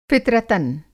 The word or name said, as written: फ़ितरतन